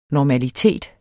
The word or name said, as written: normalitet